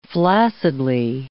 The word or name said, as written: flaccidly